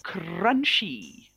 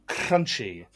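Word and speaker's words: Crunchie: Crunchy. – Very good. – Oh, beautiful pronunciation as well. – It beautifully rolled R. Well done. – Crunchy.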